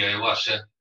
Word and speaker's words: ayahuasca: We actually became good friends, you know, and he'd done the ayahuasca, that's the way to pronounce it, several times in Amsterdam, you know.